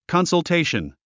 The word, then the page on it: consultation: kὰnsəltéiʃən; カンサルテイション